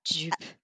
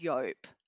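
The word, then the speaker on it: Joop: Dupe. And it's pronounced Yope.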